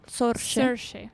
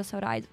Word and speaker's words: Saoirse: Saoirse. – Madonna, io ho sempre detto Saoirse.